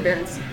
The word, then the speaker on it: Berenstain: I said Berenstain.